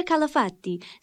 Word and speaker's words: Calafati: Hello, my name is Maria Calafati, C-A-L-A-F-A-T-I, and I'm from Greece. – I'm sorry, spell that again, please. – C-A-L-A-F-A-T-I.